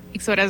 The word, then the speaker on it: Ixora: Ixora es la pronunciación correcta.